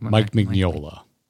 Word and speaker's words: Mignola: So if you like Slay the Spire and you love the idea of the narrator, the art, the world, that Hellboy, Mike Magnola, is that how you say it? – Mike Mignola? – Yeah.